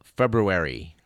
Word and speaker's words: February: the date in question being the 17th of February. We remind you that February has two R's, both of which are pronounced.